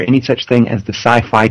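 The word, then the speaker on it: Syfy: But it's still pronounced Sci-Fi.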